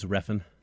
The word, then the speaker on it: Refn: I believe it's Refn. – Refn. Yeah. – I mean, listen, when you have a name that's spelled R-E-F-N, I feel like we can take whatever liberties we want in how we pronounce it.